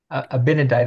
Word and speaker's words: Abinadi: Abinadi, I don't know how you pronounce that. – Abinadi, that's how I've always said it.